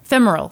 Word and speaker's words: femoral: Femoral? – Femoral, thank you.